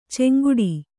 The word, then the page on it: ಚಂಗಾಟ: caŋgāṭa